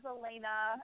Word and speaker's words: Alayna: Well, if you want to look me up online, my name is Alayna, and you spell it A-L-A-Y-N-A.